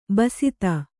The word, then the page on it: ಬಸ್ತ: basta